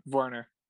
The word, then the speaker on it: Werner: Or Werner, the correct German pronunciation.